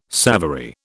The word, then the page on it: savoury: British English